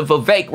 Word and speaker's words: Vivek: And the way that she stand up to Vivek rhymes with cake, you know, like, she not scared of that fool.